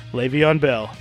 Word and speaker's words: Le'Veon Bell: I had to get rid of Le'Veon Bell. Is that how you say his name? – Le'Veon Bell, yes.